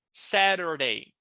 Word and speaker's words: Saturday: E aí a pronúncia, Saturday. É como se no lugar desse T aqui, deixa eu sublinhar para você aqui, assim colar aqui, eu tivesse um R.